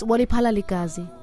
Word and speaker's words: Wali Palaligazi: Wali Falalagazi? – It's close. Wali Palaligazi.